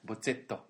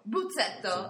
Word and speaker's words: Bozzetto: We just watched the clip of the very funny Bruno Bozzetti and. What? – Bozzetto. – Bozzetto. – Bozzetto. – Bozzetto. Grazie. And he was had a funny animation of little misunderstandings between the pronunciation of English and Italian.